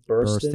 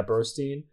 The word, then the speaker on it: Burstyn: Like, Burstyn. Burstyn. – Yeah, Burstyn.